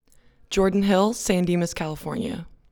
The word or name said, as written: Jordan Hill